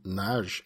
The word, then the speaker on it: Nagy: For those of you who didn't listen to the last show, his name is Von Nagy, N-A-G-Y.